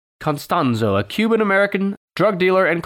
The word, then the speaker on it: Constanzo: Not Costanza, but Constanzo.